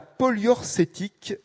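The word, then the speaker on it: poliorcétique: polyorcétique avait eu une grande importance architecturale.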